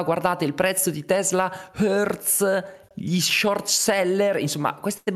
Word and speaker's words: Hertz: Elon Musk faceva battute dicendo: Ah, ah Hertz, che chiaramente è la pronuncia inglese, che significa in inglese mm, fa male, ok?